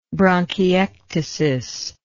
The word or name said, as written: bronchiectatic